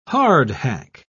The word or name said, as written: hardhack